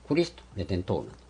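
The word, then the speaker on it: Cristo Redentor: ブラジル語でこれレッドよりちょっと違う発音にしますけど、クリスト、レデントール。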